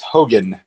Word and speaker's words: Haugen: Frances Hogan, I believe is how you pronounce her last name,